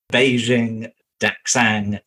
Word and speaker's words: Beijing Daxing: Beijing Daxing International Airport.